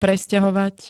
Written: presťahovať

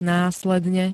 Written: následne

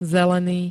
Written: zelený

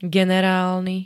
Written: generálny

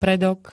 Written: predok

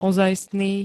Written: ozajstný

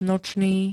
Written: nočný

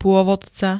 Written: pôvodca

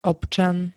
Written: občan

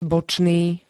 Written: bočný

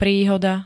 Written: PRÍHODA

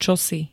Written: čosi

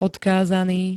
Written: odkázaný

